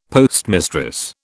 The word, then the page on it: postmistress: British English